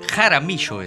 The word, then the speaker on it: Jaramillo: ¿Jaramillo lo dije bien? Oh. – Jaramillo es.